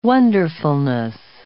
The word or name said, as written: wonderfulness